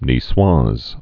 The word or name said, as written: nicoise olive